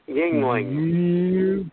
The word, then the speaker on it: Yuengling: Yuengling.